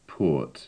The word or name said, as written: port